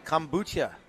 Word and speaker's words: kombucha: But that kombucha.